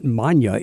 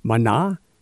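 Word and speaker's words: Maná: There's no accent on the end, so it's not Manya. It's Mana, because there's accent Mana, because the accent is on the A.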